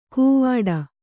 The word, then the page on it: ಕೂವಾಡ: kannada